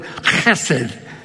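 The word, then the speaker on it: chesed (חסד): You haven't said it right if something doesn't land on the neck of the person in front of you. It's a guttural word, chesed.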